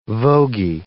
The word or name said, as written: vogie